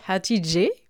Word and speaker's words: Hatice: C'est l'anecdote que vous nous partagez, mais est-ce que vous pouvez nous revenir dessus pour démarrer Est-ce que je prononce bien Hatidje euh, Oui, ça se prononce Hatidje,